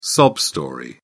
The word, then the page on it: sob story: British English